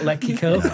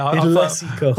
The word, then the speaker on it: Lessico: Lessico, L-E-C-I-C-O. Not yes. Not Lekico. Lekico. – Yeah. – Lessico.